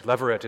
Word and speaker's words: levirate: Levirate is spelled L-E-V-I-R-A-T-E.